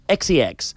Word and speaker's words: XCX: No us confoneu perquè es pronuncia XCX. És una sí. – X, una C i una X.